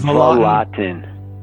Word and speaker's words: Vlautin: Vlautin. It's tough. – Just Vlautin.